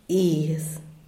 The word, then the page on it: iz: diaz_ordaz